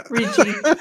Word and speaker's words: Ricci: Is it Ricci? – Yeah. – Ricci. – Okay. – Christina Ricci. Yeah.